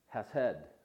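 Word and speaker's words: hesed: It's pronounced hased. And it means, this is a little meaning of it, goodness and kindness.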